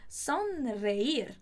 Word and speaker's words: sonreír: Sonreír. Sonreír. Muito cuidado também com a pronúncia.